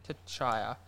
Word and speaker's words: Tchia: Chaya. – I think. – T'Chaya. – But it's spelled T-C-H-I-A.